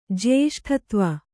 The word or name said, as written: ಜ್ಯೇಷ್ಠತ್ವ